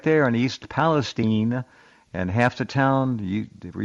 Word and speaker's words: Palestine: That's how they pronounce it there, Palestine.